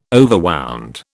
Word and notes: overwound: British English

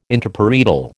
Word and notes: Interparietal: American English

